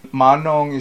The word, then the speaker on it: Manong: Manong is the right way to pronounce it.